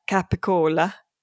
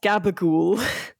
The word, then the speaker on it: gabagool: And I was struck by the pronunciation of some words that are specifically Italian-American pronunciations, like the sliced cured meat, which written down we would pronounce or would assume would be pronounced capicola, is pronounced gabagool.